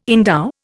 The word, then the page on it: endow: Indian